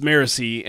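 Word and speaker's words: Marasi: Or Marassi. Good yes. – God, I'm mispronouncing my own chosen pronunciation. It's Wax, and then it's Wayne, and then it's Marisi,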